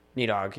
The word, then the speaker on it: Nidhogg: There's a proper way to pronounce it. I think it's... there's a TH sound in there, but it's... For our purposes, it'd be Nidhogg. – Yeah. Nidhogg, yep.